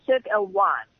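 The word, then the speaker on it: Cirque Éloize: You nearly got it right. Cirque Eloise. Try to be a bit French, darling, in the pronunciation.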